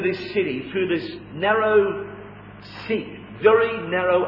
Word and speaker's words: Siq: Because the city of Petra could be entered only by a narrow sikh, as the Arabs call it. It's spelled S-I-Q.